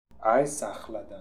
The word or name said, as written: Aiesakhaldan